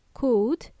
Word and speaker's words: could: By the way, when we pronounce the words would and could, the L is silent. I don't want to hear you say would or could, okay? Would and could. Okay, it's a long oo.